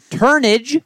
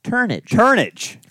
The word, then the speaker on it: Turnage: We'll also be doing a conference finals preview with the help of good buddy Peyton Turnage. – What was that? – Turnage. Yeah, phenomenal. – Sure? – Never would have guessed it. – Turnage. Just making sure you said it right.